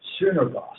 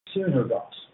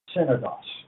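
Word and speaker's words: Sunergos (συνεργός): And that particular Greek word is officially pronounced Sunergos. Sunergos. But, however, I believe that originally it was probably translated more like synergos. – Synergos rather than synagogues, synagogues because it is really a, a, a version of the word synergy.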